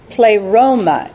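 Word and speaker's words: pleroma (πλήρωμα): Okay, so this fullness right there, is the word pleroma in Greek. I think that phonetically spelled, it's P-L-A-Y-R-O-M-A-H.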